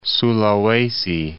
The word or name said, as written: Sulawesi